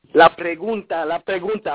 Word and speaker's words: La pregunta: Okay, Blanco, you got a song called Pergana? – La pregunta. I'm going to teach you how to say it. You're going to take it. You're going to let it spin off the top of your mouth.